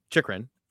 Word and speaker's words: Chychrun: Chychrun.